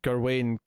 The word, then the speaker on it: Gawain: Like, I've heard the story of Gawain, however you pronounce it, in the Green Knight. – Gawain, yeah.